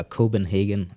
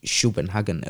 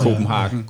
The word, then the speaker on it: Copenhagen: hvordan man udtaler Copenhagen, og ikke Schopenhagen, eller sådan eller, et eller andet. Copenhagen. Copenhagen, ja.